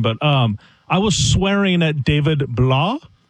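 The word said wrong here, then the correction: Blough: Purdue Boilermaker, blow. – Blows are going to blow. – The O-U-G-H is pronounced as Sam is saying it.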